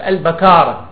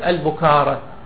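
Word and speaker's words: البَكارة: والسبب أنه في المعاجم في الصحاح في لسان العرب، في المصباح، في القاموس، في التاج، في أقرب الموارد وردت البكارة ولم ترد البكارة أو البكارة. إذن الصواب أن يقال أن تلفظ هذه الكلمة بفتح الباء.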